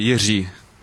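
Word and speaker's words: Jiří: No, it's actually Jiri. – Jiri. – Yeah, exactly. We are the only country in the world that can pronounce this letter Z, and everyone struggles, so it's fun for me to how they struggle and hear the different version of my name.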